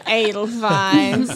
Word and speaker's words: Edelweiss: This show is best known for songs such as Edelweiss. – Yeah. – Yes. Said it right. – You know. Edelweiss.